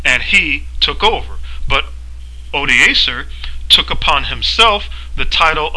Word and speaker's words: Odoacer: was forced by Odoacer, and that's spelled O-D-O-A-C-R, Odoacer, when Odoacer the Heruli forced Augustulus to quit his imperial dignity.